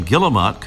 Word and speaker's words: guillemot: The name guillemot comes from French, in which the pronunciation would be something like Guillemot, derived from the name Guillaume or William.